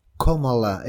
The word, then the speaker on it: Kamala: Bien, vamos a darle porque ahí ella dice Kamala, ella dice cómo se pronuncia.